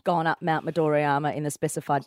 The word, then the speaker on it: Midoriyama: Moriwa. – Midoriyama.